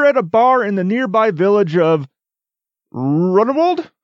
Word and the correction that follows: Ruinerwold: Ruinerwold? – Ruinerwold.